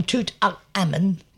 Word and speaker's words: Tutankhamun: So his dad, but I've just come back from Tutankhamun, because that's how you actually pronounce it.